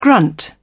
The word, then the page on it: grunt: British English